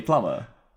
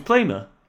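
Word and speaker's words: Plummer: How do you pronounce it? – Oh, that wasn't a joke. – No. – Oh, a plumber. – Aye. – So, surely, plumber. – I thought it was Plumer.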